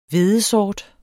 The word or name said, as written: HVEDESORT